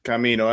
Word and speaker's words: Camino: Camino, that's it, man.